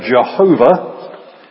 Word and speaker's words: Jehovah: His name is I Am, and in Hebrew, if you look up the Hebrew letters, transliterated, they are YHWH. And that is usually pronounced in English as either Jehovah or Yahweh.